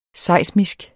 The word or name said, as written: seismisk